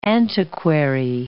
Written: antiquary

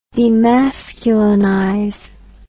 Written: demasculinize

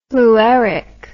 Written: flueric

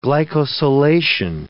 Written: glycosylation

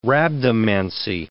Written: rhabdomancy

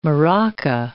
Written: maraca

